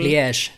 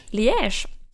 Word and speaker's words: Liège: Jag har ju hittat en studie från det belgiska universitetet Liège, kanske det uttalas, som har studerat vittnesmål. – Liège. – Liège.